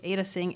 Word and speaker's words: Ada Tseng: I'll spell it for you Ada Tseng, A D A T S E N G.